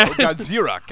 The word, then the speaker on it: Godzilla: That's right, Gorilla. – No, Godzilla. God.